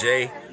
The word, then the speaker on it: Jai: Oh, my name is Artist J, but I spell it J-A-I.